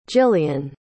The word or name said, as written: jillian